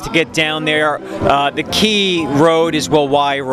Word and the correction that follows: Wawawai: Road. – Wawawi. – Thank you. It's a tough one to pronounce. – W-A-W-A-W-A-I.